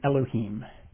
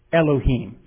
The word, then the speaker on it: Elohim: The way it's pronounced is Elohim. Why don't you say it with me? Let me listen to it one more time. Elohim. How do you say it? – Elohim.